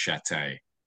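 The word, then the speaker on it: Shatté: Good, Michael, but let me let you know that my name is actually pronounced Chate.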